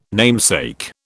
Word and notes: namesake: British English

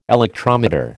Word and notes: electrometer: American English

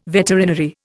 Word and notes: veterinary: Indian

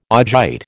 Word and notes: augite: American English

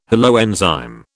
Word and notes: holoenzyme: British English

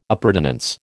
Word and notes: appurtenance: American English

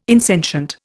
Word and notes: insentient: Indian

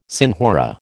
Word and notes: senhora: American English

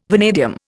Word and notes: vanadium: Indian

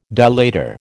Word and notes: dilator: American English

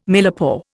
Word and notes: millepore: Indian

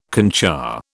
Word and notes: concha: British English

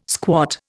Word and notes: squat: Indian

